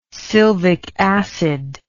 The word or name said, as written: sylvicacid